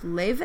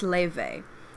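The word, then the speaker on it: Laufey: Her name, I think, is pronounced Leve. It's spelled like Lofi or Laufi, but it's pronounced Leve.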